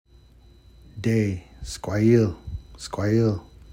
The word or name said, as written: xwelhixw skwayil